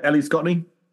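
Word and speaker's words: Ellie Scotney: Ellie Scottney? Did I say that right? – Mm-hmm. Yeah, Ellie Scottney.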